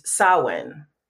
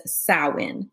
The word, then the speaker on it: Samhain: So for me, it makes sense to use the Celtic origins and the way they pronounce it is Samhain. – Mm-hmm. So Irish Gaelic says Samhain. Now let me tell you how this word is spelled. It's spelled S A M H A I N.